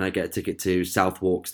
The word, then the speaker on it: Southwark: It's called, it's pronounced Southwark, right? But it's spelled South and then W-A-R-K. So me in my Spanish mode, I'm reading it literally.